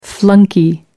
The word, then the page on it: flunky: American English